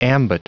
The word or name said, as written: ambit